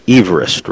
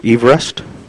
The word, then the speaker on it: Everest: Now, it should be pronounced Everest, right? Or Everest? – Well, that was the name.